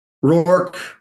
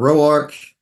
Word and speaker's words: Roark: Roark or Roark. Depends on what part of the planet you're on. – Yeah. – So Roark.